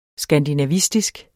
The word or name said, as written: skandinavistisk